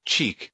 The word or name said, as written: cheek